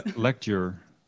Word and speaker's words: Legdeur: Le- lecture. – Lecture. Okay. – Yeah, I think that's the best way to pronounce it in English. I don't know. There's not there's not a fixed way or anything.